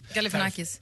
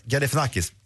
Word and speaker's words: Galifianakis: Eh, Barack Obama var ju då gäst hos, nu ska jag se, om, eh, ni kanske kan uh, uttala hans, hans efternamn bättre, Zach Galifianakis, är det rätt? – Galifianakis. – Galifianakis.